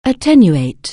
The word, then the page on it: attenuate: anglais